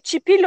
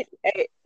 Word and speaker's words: chipil: chipil, no, chipil con una L al final.